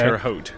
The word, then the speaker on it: Terre Haute: how do you pronounce that town? Terre Haute? Is that how you pronounce Terre Haute? Okay. Terre Haute. Yeah.